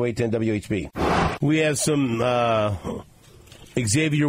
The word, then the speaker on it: Xavier: Please, for the love of all that's good and wholesome, Xavier is pronounced Xavier. There is no X in the pronunciation.